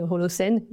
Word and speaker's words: Holocène: Donc on peut le prononcer pour les Français Holocène.